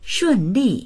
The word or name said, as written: shun4li4